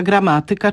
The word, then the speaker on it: gramatyka: Mówimy oczywiście matematyka, fizyka, gramatyka, czyli akcentujemy pewne wyrazy na trzeciej sylabie od końca, bo to są wyrazy pochodzenia łacińskiego.